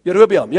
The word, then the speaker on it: Jerobeam: Jerobeam, ek weet nie wat is hy in Afrikaans, is dit Jerobeam? Nee. Jerobeam, ja, daar is die regte uitspraak. Jerobeam. Raak so 'n bietjie te mekaar partykeer met die Engels en die Afrikaanse uitspraak.